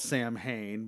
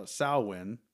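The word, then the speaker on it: Samhain: Uh, and the idea was that during this time of uh, um, um, it's pr- it's spelled Sam Hain, but it's pronounced uh, Salwin.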